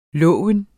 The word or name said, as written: lov